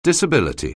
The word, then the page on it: disability: anglais